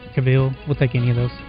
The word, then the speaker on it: Cavill: Henry Cavill, who is... – Cavill, sorry.